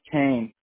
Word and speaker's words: Koehn: It's Kane, K-O-E-H-N. – Kane, that's how you pronounce it, right? – That's right. – K-O-E-H-N, correct? – That's right. – Okay. – Yep, you got it. – See, I would have said Cohen. – Yep. No, you can say it that way, too.